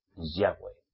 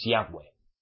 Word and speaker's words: Yahweh: Ya, pónganse en contacto, pero donde ustedes vean YHWH, Yahweh. Pronúncialo. A solas con él. Y si alguien va mesiánico a decirles, es el Eterno, no le hagan caso. Es Yahweh.